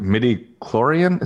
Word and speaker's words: midi-chlorian: And what, and the other thing I don't like either is that midi-chlorian, is that how you say it? – Yeah, that's what gives you the Force?